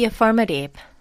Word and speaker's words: affirmative: Be affirmative. Be affirmative. Be positive. Be positive. 네, 여기까지. Affirmative 발음이 좀 어렵죠. Affirmative. Affirmative. Spelling이 어떻게 되냐면요. A-F-F-I-R-M-A-T-I-V-E. Affirmative. Affirmative.